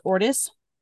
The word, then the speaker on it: Ortis: So his name is Ortis, to rhyme with tortoise, unless you pronounce tortoise tortoise, in which case it doesn't.